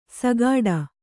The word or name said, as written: ಸಗಾಡ